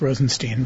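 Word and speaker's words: Rozenshtein: Pronounced Rosenstein, but Rosenstein, of course, where the H is silent.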